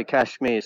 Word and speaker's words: Kashmir: Kanyakumari to Kashmir.